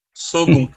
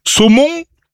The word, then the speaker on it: Saulgond: 27 ans, Sogon. – C'est où ça, Sogon ? – C'est en Charente. – Sogon ? – Sogon. – Saumon ? – Sogon. – Sogon, ça s'écrit comment ? – S-A-U-L-G-O-N-D. – S-A-U-G-O-L-B ? – G-O-N-D.